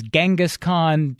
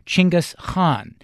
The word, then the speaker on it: Chinggis Khan: You probably know it as Genghis Khan. During the interview, I say Genghis Khan, though from time to time I slip into Weatherford's more informed pronunciation of Chinggis Khan.